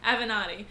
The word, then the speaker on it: Avenatti: No, even, I feel like maybe what you're specifically referring to even is Michael Avenatti. Is that how you pronounce his name? – Avenatti.